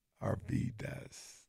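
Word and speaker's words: Arvydas: A-R-V-Y? – That's it. – Arvidas. – You got it. – Well, D-U-S? – No.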